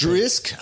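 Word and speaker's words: Dricus: Duplessis, do, do but it's Drisk. – I think it's Drisk. His first name is pronounced Drisk.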